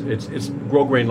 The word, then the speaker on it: Grosgrain: It's Grosgrain, like the fabric or the... – Right. – The ribbon. – There's an S in there.